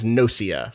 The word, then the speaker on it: Gnosia: Yeah, like I keep forgetting how to pronounce Gnosis, right? Is that how we're saying the game? – I think the name of this game is Gnosia.